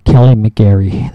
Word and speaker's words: McGarry: Anyways, Kelly McGarry, that's right.